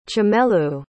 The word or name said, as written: chimelu